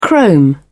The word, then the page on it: chrome: British English